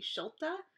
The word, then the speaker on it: Schulte: Um, the German pronunciation of it um, is actually Schulte, or like it comes from Schulteis, which sounds really oddly like Edelweiss.